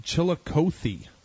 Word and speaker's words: Chillicothe: But we did get an instant message on the pronunciation of that name. It's Chillicothe. – Okay, Chillicothe.